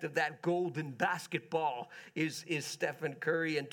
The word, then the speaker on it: Stephen Curry: And that's Stephen Curry.